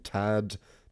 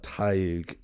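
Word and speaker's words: Tadhg: You'd just think Tadig, but Tig is how it is pronounced.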